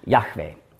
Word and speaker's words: Yahweh: Den man schreibt mit J-H-W-H. Richtige Aussprache, Yahweh.